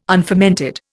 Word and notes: unfermented: Indian

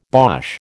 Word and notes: bosh: American English

